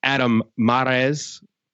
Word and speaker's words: Adam Mares: He's also the host of Locked On Nuggets, Adam Mares.